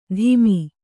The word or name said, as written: ತಿಮಿ